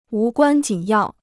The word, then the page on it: 无关紧要: wú guān jǐn yào